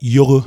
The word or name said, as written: Yoho